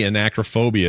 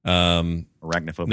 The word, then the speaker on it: Arachnophobia: Something called Argo, 93 Matinee, 1990 Anacrophobia is 91%. – Arachnophobia.